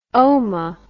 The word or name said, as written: oma